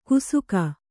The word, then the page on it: ಕುಸುಕ: kusuka